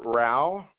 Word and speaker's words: Rauh: My name's Stuart Rao. – First name is spelled S-T-U-A-R-T. Last name spelled R-A-U-H.